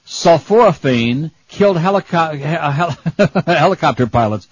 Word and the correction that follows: Helicobacter pylori: How do you say that? Helicobacter pylori.